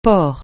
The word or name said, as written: porc